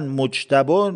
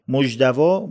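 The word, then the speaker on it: مجتبی: هر جایی هم یه تلفظی داره. مثلا مجتبا 9 جوره. مجدوا، مجتبا، مجدبا، مجتبا، مجتبا، مجتبا، مجدوا.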